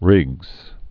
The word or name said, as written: Riggs' disease